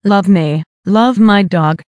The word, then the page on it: love me love my dog: American English